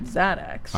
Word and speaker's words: Xaddax: and then um, if we can just sort of fast forward now you you are um, a, a member of Xaddax. – I am. Is that the way I pronounce yes, it? Yes, that's correct. X A D D A X.